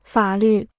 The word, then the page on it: 法律: fǎ lǜ